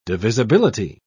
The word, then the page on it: divisibility: British English